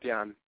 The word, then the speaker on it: Jann: It's Jann Klose. Hi. – Hi, hi. So, Jann, tell us a little bit about yourself. – Just, my name is pronounced Jann.